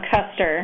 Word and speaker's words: Koester: No, it's Koester.